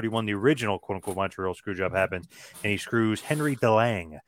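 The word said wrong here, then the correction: Henri Deglane: or I think the correct pronunciation is Henry DeGlain.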